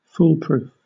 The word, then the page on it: foolproof: British English